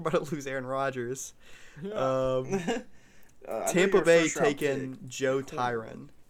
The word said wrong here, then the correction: Tryon: Tryon, sorry.